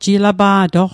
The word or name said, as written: jilapa'toq